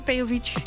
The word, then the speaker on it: Peowitz: Spreek je achternaam goed uit? – Ja, Peowitz. – Peowitz, ja. Heel goed.